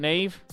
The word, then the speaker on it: Niamh: How do we spell it, Neve? – N I A M H. – Oh. – H. – We would never get that, Neve.